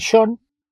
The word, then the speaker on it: Sean: En Sean, que el Sean que escribe Sean es igual que el que escribe Shaun con U y que el que escribe Shaun con W. Se pronuncian igual los tres.